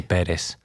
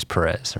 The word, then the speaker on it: Perez: It's definitely Perez, the right way to pronounce it. But, you know, growing up in the U.S. and Dallas and then Houston, it's always Perez, right? – Perez, okay.